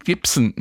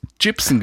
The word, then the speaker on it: Gibson: Der legendäre Synthesizer Moog wird nicht Moog und Gibson, nicht Gibson ausgesprochen.